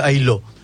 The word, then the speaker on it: Ailhaud: Violette es A-I-L-H-A-U-D. – U-D, la... No, está bien. Sí, la D no se pronuncia. – Ailot. Y les cuento por qué estoy fascinado.